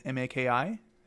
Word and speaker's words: Maki: And I mean, you can find me at Justin Maki Music, last name spelled Maki.